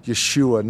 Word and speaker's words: Yeshua: which would have been Yeshua, not Jesus as we say it now.